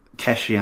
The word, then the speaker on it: Keshi: Is it Kelchi Anderson? Is that how you say it? – Keshi Anderson. Keshi Anderson. We call him Lean or Keshi.